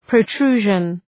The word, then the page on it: protrusion: prəʋ’tru:ʒən